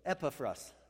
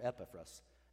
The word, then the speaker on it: Epaphras: So Epaphras, I said it wrong. It's more Epaphras.